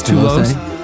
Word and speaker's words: Toulouse: How would you say it? It's Toulouse. T-O-U-L-O-U-S-E. Toulouse. There you go.